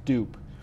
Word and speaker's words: DUP: So there's a word in fourth spelled D-U-P. It's pronounced dupe.